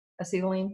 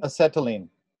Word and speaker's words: acetylene: I know I didn't say that when I get, did I say it right this time? Acetylene? – Acetylene. – Acetylene. Okay. That's kind of like gluten.